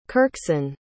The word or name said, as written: kyrksen